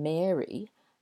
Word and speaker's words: Mary: So to me, the girl's name, Mary, that rhymes with scary or hairy. He's got a lot of hair. He's very hairy. Or airy. That room is airy. Oh, that's a really weird sentence, isn't it? Whereas the boy's name, Gary, G-A-R-Y, that rhymes with Harry, as in Harry Potter, or Prince Harry. And so Mary, the girl's name, has an air vowel sound, and Gary, the boy's name, has an ah sound.